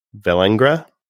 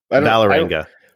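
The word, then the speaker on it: Vålerenga: Vellengra. – It's the A with the circle over it, which I do not know what to call that. So I don't know how to pronounce it correctly.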